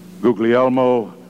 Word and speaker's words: Guglielmo: a gentleman by the name of Guglielmo Marconi, and I'm sure I pronounced his first name correctly, as someone didn't earlier tonight.